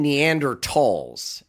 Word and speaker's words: Neanderthal: And I'm going to stop you right there, because you're saying Neanderthals. And every time you say it, it bugs me, but it is the proper way to say it. Why has everybody been saying Neanderthals? – It doesn't bother me if how people pronounce it. I think it was Tall, T-H-A-L, means valley in German. And the first skeletons of a Neanderthal were discovered in, I think it was 1856, in the Neander Valley in Germany.